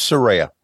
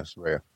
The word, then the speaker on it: Saraya: Is it Saraya? Cause I don't think I was saying Saraya before. – It's Saraya. – Yeah. – Right. Yeah. Saraya.